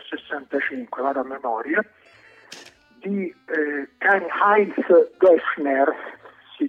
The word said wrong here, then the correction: Deschner: dice, lo pronuncio bene perché ho chiesto, si dice dolce o si dice duro? No, Deschner, dolce,